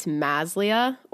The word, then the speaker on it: Maslia: Like it's Maslia or Maslia. I don't care. Like Maslia, Maslia, not Mislia, not Maslia, not Melissa.